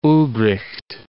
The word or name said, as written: Ulbricht